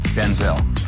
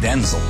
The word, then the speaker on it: Denzel: My name's not pronounced Denzel. Pronounced Denzel. My father's Denzel Hayes Washington Sr. My mother would say, Denzel, we both show up. She said, from now on, you're Denzel.